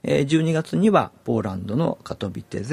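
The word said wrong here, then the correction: Katowice: で、 COP24、ちょっと、発音が難しいね。カトビセ。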